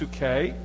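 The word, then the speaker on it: psuche (ψυχή): But the Greek word that is used for soul is pronounced psuche.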